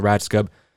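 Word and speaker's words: Rajskub: Yeah, Fox's hit television series 24, which, who, by the way, one of the main stars of that show was, her name is Marilyn Ratscub. I don't know how to pronounce her name, but she plays Gail the Snail in... – You got it.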